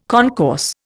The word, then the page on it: concourse: Indian